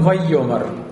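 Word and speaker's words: «вайомар» (וַיֹּאמֶר): Но когда он вот в этом ваектоле, он «вайомар».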